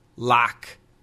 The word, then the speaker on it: lock: This is L-U-C-K. Luck. Luck. Luck. That is the short U. Luck. L-O-C-K. Lock. Lock. Ah. Ah. That is the short O. Ah. Lock. Lock. And L-O-O-K.